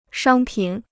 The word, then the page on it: 商品: shāng pǐn